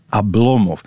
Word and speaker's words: Oblomov: Ik zeg nu Oblomov, maar in moet je het eigenlijk anders zeggen. – Ablomov. Die onbeklemtoonde O wordt een A. Maar zeg maar gewoon Oblomov, met de klemtoon op de tweede lettergreep.